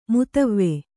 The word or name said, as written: ಮುತವ್ವೆ